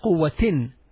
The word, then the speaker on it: قوة: قوة, قاف, واو, and round ta. And there is two dashes under the round ta, so pronounce it قوة.